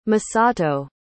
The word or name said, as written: Masato